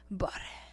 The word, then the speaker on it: barre: Así apenas se escribe barre, pero se pronuncia como si dijéramos bar. Y así a lo lejos el re. Barre.